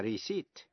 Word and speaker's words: receipt: A-C-C-U-R-A-C-Y, accuracy. Và precision, P-R-E-C-I-S-I-O-N. Cũng ôn lại những chữ dễ phát âm sai như là chữ key, Q-U-A-Y, nghĩa là bến tàu. Debt, D-E-B-T, nghĩa là nợ. Và receipt, R-E-C-E-I-P-T, là biên lai. Phần chính của bài học hôm nay là cách phát âm tiếp vĩ ngữ I-O-U-S.